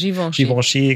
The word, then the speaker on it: Givenchy: Also LVMA hattest du gerade schon genannt mit Dior und Kenzo und Givenchy. Ich weiß gar nicht, ob man es richtig ausspricht. – Givenchy, genau.